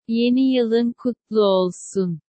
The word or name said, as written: yeni yilin kutlu olsun